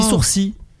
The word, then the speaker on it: sourcil: On dit sourcil. – Le L est muet. Ouais. On dit sourcil.